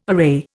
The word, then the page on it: array: Indian